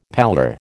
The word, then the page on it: pallor: American English